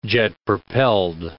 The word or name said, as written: jet-propelled